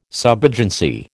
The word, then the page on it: subagency: American English